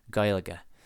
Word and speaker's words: Gaeilge: Where I know that, yes, the pronunciation of Gaelic in Irish when you're speaking the language doesn't sound like Gaelic. It sounds like, just as Sam lays it out here, Gaeilge.